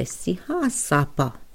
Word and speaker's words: Sihasapa: And acknowledgement to the Blackfeet relatives, there is the Sihasapa Oyate. The Sihasapa Oyate. Sihasapa. Okay, so if we want to pronounce those together, Sihasapa Oyate.